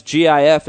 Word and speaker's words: GIF: The correct way to pronounce GIF is with a soft G, as in gin.